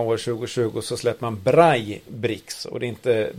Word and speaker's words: Braille: Braille uttalas det på franska. Braille. – Fast två L är på spanska, blir det J.